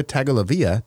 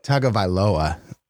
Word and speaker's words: Tagovailoa: I'm a little bit concerned that we are getting, again, wrapped up in this quarterback talk where Joe Burrow and Tua Tagovailoa, did I say his name right? – No, it's Tagovailoa. – Oh, I said it way wrong.